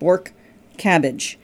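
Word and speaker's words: Bortkiewicz: She's originally from Bulgaria, and she introduced us to Borkevich, and she told us to pronounce it like Bork cabbage.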